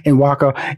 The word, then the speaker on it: Nwaka: Please welcome to Money Making Conversation, Nwaka Onwusa.